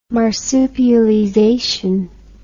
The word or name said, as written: marsupialization